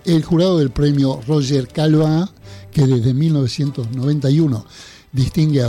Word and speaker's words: Caillois: El escritor y traductor Eduardo Berti, tengo el honor de anunciarles que ha sido galardonado con el premio Roger Calva. Para que algunos tengan memoria gráfica, se escribe Caillois, seguramente lo recordarán.